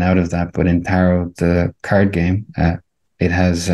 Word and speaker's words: tarot: My Italian pronunciation, it wouldn't be great, but the name that we call it, tarot, without pronouncing the T at the end, is the French version.